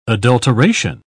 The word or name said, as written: adulteration